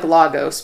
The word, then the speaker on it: Lagos: It's spelled like Lagos, but they say Lagos, so that's just what we've been calling it.